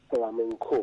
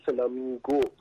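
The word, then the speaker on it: flamenco: که اشتباهاً فلامینگو تلفظ میشه، که فلامینگو اسمی که پرنده است. – بله فلامنکو. – فلامنکو. بله. در از این فلاح منگوم هست. – در واقع اسپانیایش میگه فلامنکو.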